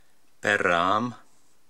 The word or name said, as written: Berraam